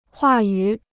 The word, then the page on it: 话语: huà yǔ